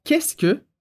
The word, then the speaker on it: qu'est-ce que: E esse primeiro qu'est-ce que significa o quê, que tem várias letras quando a gente escreve, mas a pronúncia é super simples.